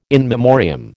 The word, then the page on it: in memoriam: American English